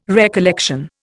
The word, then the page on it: recollection: Indian